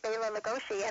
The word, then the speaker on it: Bela Lugosi: Very often people would pronounce it as Bela Lugosi on radio and in movies, and even today it's mispronounced, but that's the correct pronunciation of his name. And very often if you hear him at the beginning of a program, when he's introducing himself, he will definitely put the accent on Bela.